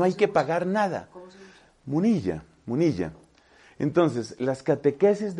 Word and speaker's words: Munilla: Hay un obispo relativamente joven en España, de apellido Munilla con doble L, Munilla, habría que pronunciar.